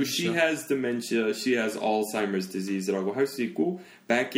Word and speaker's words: Alzheimer's: 그냥 Alzheimer's disease. 그래서 아. 항상 그 S 붙여서 말합니다. – 그러니까 어에 가깝네. R이 아니고. – 네, Alzheimer's. Alzheimer's disease이라고 하고요.